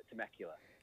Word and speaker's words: Temecula: Temecula.